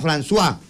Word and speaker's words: François: Pero se escribía Francois, pero se pronunciaba François.